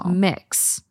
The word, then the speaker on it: Mx: And it's MX, pronounced mix.